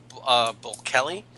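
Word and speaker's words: Bulkeley: It's pronounced Bulkelly because I actually phoned the local historical society, and there is the New London Historical Society.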